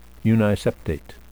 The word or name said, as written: uniseptate